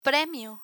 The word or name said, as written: premio